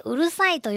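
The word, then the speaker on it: 五月蝿い: よう、当てたなぁと思いますけど、 5 月ハエイって書いて、うるさいと読みます。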